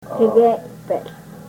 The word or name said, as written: kegeypehl